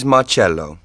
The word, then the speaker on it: Marcello: My name is Marcello. – How do you spell it? – M-A-R-C-E-L-L-O.